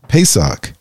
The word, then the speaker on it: Pesach: And about the truth around Easter, because as believers, the Passover, or pronounced Pesach, develops the importance of blood sacrifice.